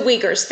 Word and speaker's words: Uyghurs: God, I hope I'm getting this pronunciation right. The Uyghurs, thank you.